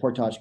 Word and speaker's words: portage: Yes, I said portage because I'm Canadian. That's how you say it.